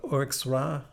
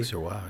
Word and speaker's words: Auxerrois: There is a Auxerrois. I'm not pronouncing that correctly. – Auxerrois.